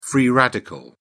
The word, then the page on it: free radical: British English